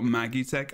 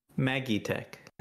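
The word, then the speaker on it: Magitech: So you're still with a J pronunciation of the G. So that's still Magi Tech or Magi Tech. But you're you're hedging your bets, Jebro, and you're saying it's an E.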